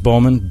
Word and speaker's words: Baughman: Uh, I guess it's pronounced Baughman.